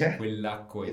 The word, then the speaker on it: quell'acqua: if it was this water, that water, it would be quell'acqua. – Yeah, quell'acqua. You chose a bit of a tricky one to pronounce, but yes, quell'acqua is perfect.